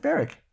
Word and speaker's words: Berwick: Does she pronounce it Berwick or Berwick? – No, Berwick. – Oh. Why is it Berwick? – Like Warwick.